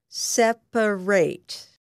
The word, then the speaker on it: separate: It is pronounced like this: separate. When separate is an adjective, pronounce the last syllable of the word as ret.